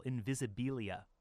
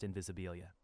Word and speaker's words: Invisibilia: I want to say that with an extra syllable, Invisibilia, but I think it's just Invisibilia.